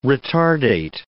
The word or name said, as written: retardate